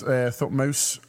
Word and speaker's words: Thutmose: Yeah, I think it's pronounced Thutmose. I've spelt it wrong, which doesn't help. Just spell it now for everyone. – People love it. – T-H-U-T. – Play the hits. – M-O-S-E.